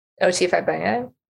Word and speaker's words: O.T. Fagbenle: O.T. Fagbenle? – Yeah, there you go.